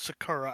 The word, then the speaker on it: Sakura: It is pronounced Sakura.